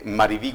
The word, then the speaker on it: Maribí: No tan difícil, pero es que antes de presentarlo hacía el comentario de que en Brasil le costaba pronunciar su nombre, le decían solo Marvi o Mari. – Solo Mari. – Mari, ajá. Así. Bueno, es Maribí Godoy, ella es actriz igualmente.